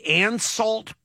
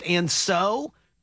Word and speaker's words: Ansault: And they said the closest thing to it is the Ansault pear, or maybe it's pronounced Anso, A-N-S-A-U-L-T, the Ansault pear, also falls into that category of it was a very distinctive pear that is gone.